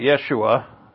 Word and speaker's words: Yeshua: And the Jews now, they know about Yeshua. That's the Hebrew pronunciation of his name.